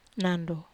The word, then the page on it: nando: wamesa